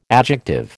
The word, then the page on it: adjective: American English